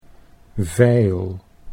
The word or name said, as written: veil